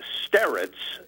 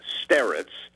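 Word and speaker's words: starets: S-T-A-R-E-T-S. S-T-A-R-E-T-S. Sterets, or one variation is to put a kind of Y sound in front of that last syllable, sterets, if that makes any sense.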